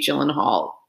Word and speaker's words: Gyllenhaal: Let us know if you think Jake Gyllenhaal needs to give her back a scarf.